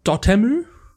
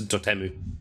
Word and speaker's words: Dotemu: Bodacious game developed by Tribute Games and published by Dotemu, who also published Street of Rage 4 and Wonder Boy the Dragon's Trap. Sorry, how do you pronounce it? – I think it's Dotemu. – Dotemu.